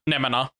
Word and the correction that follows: Nemona: Oh yeah, it's Nemona, not Nemana.